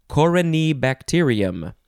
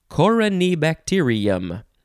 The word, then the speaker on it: corynebacterium: Okay, so this word is corynebacterium. Corynebacterium. It's all one word. Coryne is C-O-R-Y-N-E. Just making sure I am pronouncing it correctly. You could also say corynebacterium.